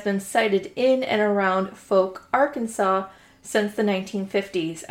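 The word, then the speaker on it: Fouke: And I thought it was almost pronounced Fook, but I had to pull up the Google pronunciation just to make sure I wasn't fucking it up. – Yeah, so it is pronounced Folk. – Yeah, rhymes with poke.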